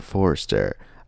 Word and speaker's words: Forster: Robert Forster, if that's how you pronounce his last name.